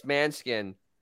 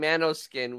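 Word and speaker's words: Måneskin: It's Manskin. And he was saying Manoskin, which he's a moron.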